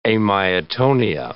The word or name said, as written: amyotonia